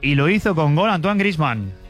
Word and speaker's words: Antoine Griezmann: That is number 300 across international and club games for that man, Antoine Griezmann. – Antoine Griezmann, lo pronuncian bien, la verdad.